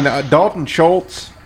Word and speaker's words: Dalton Schultz: I mean, Dalton Schultz, if I'm saying his name right.